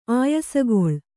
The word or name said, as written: ಆಯಾಸಗೊಳ್